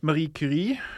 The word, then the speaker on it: Marie Curie: Marie Curie. – Marie Curie. Man skal ha litt sånn gode ærer der. Marie Curie. – Du kan jo si Marie Curie, hvis du vil. – Marie Curie.